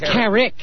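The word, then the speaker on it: Kerik: Ronnie says it's Bernard Carrot. – Yeah, Bernard Carrot. – Carrot? – How do you spell that? K-E-R-I-C-K? – I think it's K-E-R-E-K. I don't think it's Carrot. I think it's Carrick.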